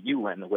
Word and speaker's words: Ulin: It's Ulin, which is spelled U-L-I-N, and it's often mispronounced Ulin or Ullin.